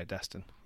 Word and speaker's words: Destin: Why does he say his name like that? – It's spelled that way. D-E-S. – Is it D-E-S? – Yeah, Destin.